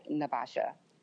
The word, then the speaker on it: Nai'vasha: I just go by Naivasha.